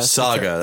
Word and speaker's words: Saga: No, Saga, that's a long A.